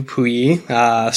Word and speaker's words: Puyi: It's John Lone, who played the adult Puyi, not Piyu, Puyi.